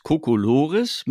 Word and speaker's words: Kokolores: das sind die Kokolores, die sind alle geschlossene O's, Kokolores mit Betonung auf dem dritten.